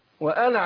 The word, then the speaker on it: وأنا: كلمة انا برضه الالف بتاع انا عليه دايرة. ده معناه ايه ان الالف هنا ايه لا ينطق. – نعم. – اقول وانا على وأنا على.